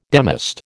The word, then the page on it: demist: American English